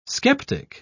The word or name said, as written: skeptic